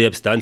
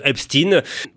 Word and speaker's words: Epstein: Pendant longtemps, l'affaire Epstein, nous allons prononcer Epstein, certains disent Epstein.